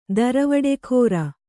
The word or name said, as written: ದರವಡೆಗಾರ